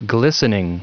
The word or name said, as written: glistening